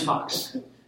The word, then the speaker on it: Fox: So, yes, my name's Fox. Spell it F-O-X, right?